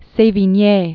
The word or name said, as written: Sevigne